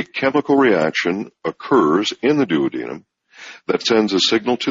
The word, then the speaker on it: duodenum: And this is below your stomach, and it's called the duodenum, or in some countries they pronounce it duodenum. Tomato, tomato, however you like.